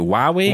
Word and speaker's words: Huawei: Or not Huawei. Is that how you say it? Huawei? – Huawei. – That's watch. The official way to say it, yes.